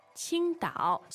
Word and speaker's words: 青岛: So if you're drinking a Qingdao beer in the United States or Europe or somewhere, here's your pronunciation. It's 青岛. – So it's first tone and third tone.